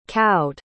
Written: cowed

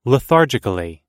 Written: lethargically

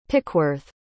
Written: pickworth

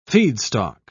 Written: feedstock

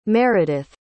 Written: meredith

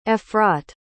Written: efrat

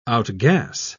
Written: outgas

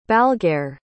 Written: balgair

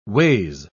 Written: ways

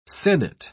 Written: senate

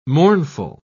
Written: mournful

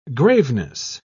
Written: graveness

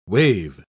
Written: waive

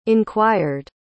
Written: inquired

